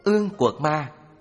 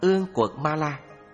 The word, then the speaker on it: Ương Quật Ma: Ương Quật Ma, phiên âm từ Phạn ngữ là Anguli Maliya, cũng đọc là Ương Quật Ma La, Ương Cừu Ma La hay Ương Quật Man, dịch nghĩa là chỉ man, nghĩa là dùng ngón tay người kết làm vòng để đội trên đầu.